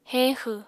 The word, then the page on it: heñxw: Apatani